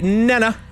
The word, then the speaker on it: Nena: You got to say it like Nena. That's how it's the proper way of saying it.